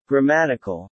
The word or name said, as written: grammatical